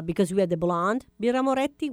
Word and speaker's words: Birra Moretti: Let's say it right Birra. – Birra. Birra. – Moretti. – Birra Moretti. – Oh. – Birra Moretti. Ah, Bravo. vabbè. So the two T.